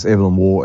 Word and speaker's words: Evelyn Waugh: That's Evelyn Waugh as in Jesse Waugh, same pronounced spelling.